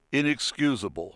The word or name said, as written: inexcusable